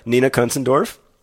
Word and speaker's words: Nina Kunzendorf: Nina Kunzendorf. Terrific performance in this. – Perfect and perfect pronunciation.